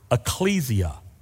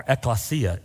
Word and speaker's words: ecclesia: And we got into this big discussion about the word ecclesia or ekklesia, either way you want to pronounce that.